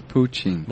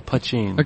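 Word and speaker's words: poitín: Is that how you say it? I thought it was poutine. – No, putching. – Okay. – Right. – And P-O-I-T-I for the N.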